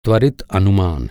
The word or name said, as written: त्वरित